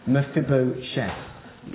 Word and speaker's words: Mephibosheth: Well, like our reading spoke about this morning, and Kelly so well pronounced, Mephibosheth.